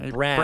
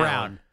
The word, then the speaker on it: Braun: We know how it's spelled. For some reason, he says it Brown. Brown.